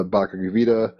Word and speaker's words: Bhagavad Gita: And it's obvious that you are very into Krishna, very into the Bhagavad Gita, however one pronounces that.